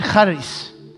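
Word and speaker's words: charis (χάρις): The Greek word for grace is the word charis. Charis, it's actually with a C in English, spelled with a C, but actually there's no C. It's C-H-A-R-I-S. It's a silent C.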